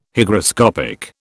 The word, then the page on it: Hygroscopic: British English